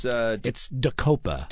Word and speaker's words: Daqopa: It's Dacopa. – Dacopa. – D-A-Q-O-P-A.